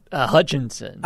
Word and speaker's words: Hutchinson: Asshat Hutchins, did I say that correctly? – Hutchinson.